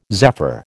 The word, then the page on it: zephyr: American English